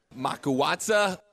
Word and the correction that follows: Wakamatsu: Wow. – It's Wakamatsu.